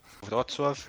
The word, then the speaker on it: Wrocław: Como que fala aí? Wrocław.